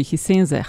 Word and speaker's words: Hisense: Um, se pravi, Gorenje je pred časom prevzel kitajski Hisense. A se to tako izgovori, Hisense? – Hisense.